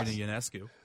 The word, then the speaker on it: Ionescu: Ionescu? Did I say her name wrong? – I think it is. I think that I is soft. – Okay. It's a soft I.